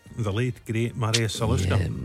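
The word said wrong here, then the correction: Žaliūkas: Zaliuskas. – I'll let you away with the mispronunciation. Zaliuskas.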